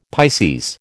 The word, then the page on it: Pisces: American English